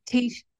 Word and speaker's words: Taoiseach: the Tiersek is the title? – Taoiseach. – Taoiseach. That's how you pronounce it.